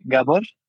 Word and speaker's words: Gabor: It's actually Gabor. It's a Hungarian name. I'm originally from there.